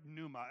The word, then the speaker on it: pneuma: And again, the poor in spirit, this is the tough thing because, of course, spirit, or pneuma, it's P-N-E-U-M-A. Is that pneuma? I always say pneuma because let's pronounce the P for the fun of it.